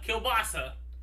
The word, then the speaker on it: Kielbasa: If you've heard Dr. Papa Kielbasa, and I have to say that in three syllables.